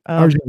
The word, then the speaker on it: Arjun: I think it had some problems, but I thought, you know, Arjun, I'm going to say his name wrong. – Arjun, yeah, A-R-J-U-N.